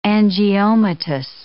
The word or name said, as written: angiomatous